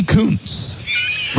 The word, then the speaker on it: Kuntz: Rusty Kuntz. Rusty Kuntz. It's spelled K-U-N-T-Z.